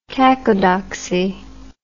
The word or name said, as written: cacodoxy